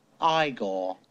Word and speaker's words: Igor: No, it's pronounced Igor.